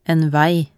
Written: en vei

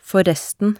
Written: forresten

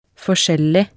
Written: forskjellig

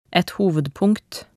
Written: et hovedpunkt

